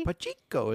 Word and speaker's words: Pacheco: Clyde Edwards-Helaire had 7 carries compared to Isaiah Pacheco. Did I say that correctly? – Pacheco is what Pacheco.